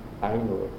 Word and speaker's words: Ainu: And the original native were the Ainos. – Pardon me? – Ainos. They are the original inhabitant of Japan. – Could you spell that for me? – Ainu, A-I-N-U. Ainu. – A-I-N-U.